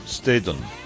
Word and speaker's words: Staton: ¿Cómo lo pronuncias tú? – Staton. – Staton. Ah, pero, o sea, que lo hace más fricativa, ¿no? – Un poquito más fricativa. La T, tú sabes, en americano, no en inglés, ¿eh?